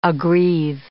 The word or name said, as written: aggrieve